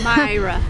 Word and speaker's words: Maira: Try it. Let's see. – Maya. – Myra. – Myra. – Yes, sir.